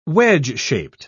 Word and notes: wedge-shaped: British English

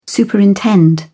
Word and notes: superintend: British English

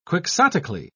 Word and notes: quixotically: British English

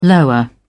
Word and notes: lower: British English

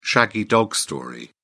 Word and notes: shaggy dog story: British English

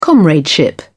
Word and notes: comradeship: British English

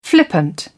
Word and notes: flippant: British English

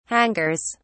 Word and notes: hangers: British English

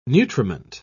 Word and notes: nutriment: British English

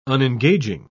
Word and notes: unengaging: British English

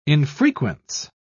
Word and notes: infrequence: British English

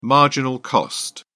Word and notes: marginal cost: British English

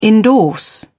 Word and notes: endorse: British English

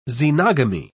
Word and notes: xenogamy: British English

